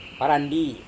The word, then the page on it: warandi: Serewen